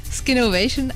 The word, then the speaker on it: Skinnovation: Wie heißt es korrekt? – Skinnovation, aber ich glaube, da gibt es ja mehrere Aussprachen, weil das nicht ganz so klar ist. Also ja, sind da flexibel.